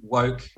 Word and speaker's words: Wowk: Woke is W-O-W-K. If it's easier, you can remember it as Wowk. That's fine. I'm not fussy about the pronunciation of my name.